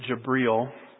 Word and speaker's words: Jibril: They pronounce it Jibril.